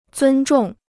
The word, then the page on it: 尊重: zūn zhòng